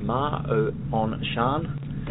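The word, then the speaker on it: Ma On Shan: And we had the most stunning view running across, and you might help me with the pronunciation. She was at Ma O On Shan. – That sounds fine.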